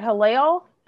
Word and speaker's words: Hillel: Yeah, and so you mentioned that you worked at Hillel.